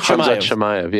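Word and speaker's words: Khamzat Chimaev: I was like, yeah, I got this guy, Kamazat, and I butchered the name. – Oh, yeah. Shamayev. Hamzat Shamayev, yeah.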